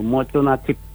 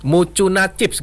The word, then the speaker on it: Mucuna Chips: Tadi kalau di awal saya udah nyampaikan bahwa sebutannya salah nggak tadi saya ngomongnya, yang pakai bahasa Inggrisnya tadi, boleh diralat dulu deh. – Iya, itu memang betul itu, Mucuna Chips gitu. – Mucuna Chips gitu bacanya ya? – Iya, betul, betul sekali.